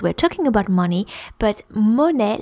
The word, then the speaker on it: monnaie: That's the first one is monnaie, and it's spelled M-O-N-N-A-I-E, monnaie.